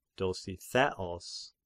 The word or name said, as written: Dositheos